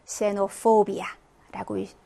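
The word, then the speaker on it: xenophobia: 단어의 첫 글자로 쓰일 때는 S 발음 납니다. 그래서 xenophobia라고 읽으시면 되고 xylophono라고 읽어주시면 됩니다.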